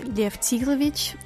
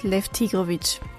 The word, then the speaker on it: Lev Tigrovich: Russisch ausgesprochen wäre es Lev Tigrovich, aber ich sage es ganz oft eingedeutscht Lev Tigrovich.